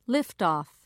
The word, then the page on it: liftoff: British English